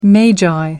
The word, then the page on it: magi: American English